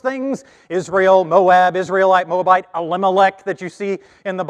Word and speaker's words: Elimelech: But if you want to know how to say it, it's Elimelech, and then Moab and the Moabites.